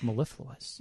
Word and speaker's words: mellifluous: I think it's mellifluous. – Yeah. – Four syllables.